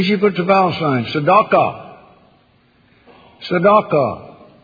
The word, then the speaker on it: tzedakah: And D, and then D-A, no, D-A, and then Q-A, and H, silent H on the end. Tzedakah. You recognize that word? – Yes, sir. – Let's write it underneath it, S-E-D-E-Q-A.